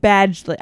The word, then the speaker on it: Badgley: Badgley. I was calling him Bagdley with a B.